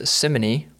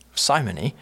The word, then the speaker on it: simony: a word that can be pronounced two different ways, simony or simony.